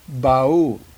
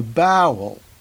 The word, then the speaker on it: Ba'ul: The Ba'ul, the predator species on Saru's world, is pronounced by most of the crew, including Saru, who should know just the way it's spelled. B A apostrophe U L Ba'ul. But at least one crew member refers to the species as the bowel and I'm pretty sure I heard another member of the crew call them the bowl.